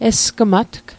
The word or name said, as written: esgmatg